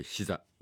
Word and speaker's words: SZA: SZA と書いてシザです。